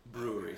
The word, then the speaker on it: Bruery: Well, it's spelled B-R-U-E-R-Y, brewery. So yeah, it's a little bit hipster.